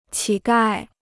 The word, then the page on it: 乞丐: qǐ gài